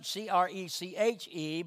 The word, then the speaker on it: creche: I've never heard of that word, and it's pronounced it's spelled C R E C H E, but it's pronounced creche.